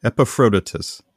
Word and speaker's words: Epaphroditus: Like you said, his master, and I'm really going to pronounce this poorly, his master was Epaphroditus. Epaphroditus. There we go.